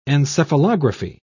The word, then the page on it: encephalography: British English